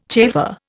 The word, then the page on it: chafer: Indian